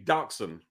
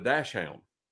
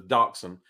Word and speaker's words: dachshund: We call it it would be called a dachshund would be the proper pronunciation. But some people call it a dash hound. Because if you look at the way it's spelled, it looks like dash hound. But the proper pronunciation is dachshund.